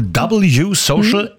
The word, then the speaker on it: W Social: Wird geschrieben Weh Social, ausgesprochen wohl W Social.